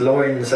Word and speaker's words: Loynes: It is Dutch. It comes from a Dutch. So it's spelled L-O-Y-N-E-S, which is a very odd spelling, and most people can't pronounce it, and sort of lions and lions, blah blah blah, because I think they're used to L-Y-O-N-S, right? – Yeah, the French. – Exactly. But yeah, it's loins, and it's Dutch, and it's from like fishermen or something.